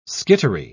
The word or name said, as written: skittery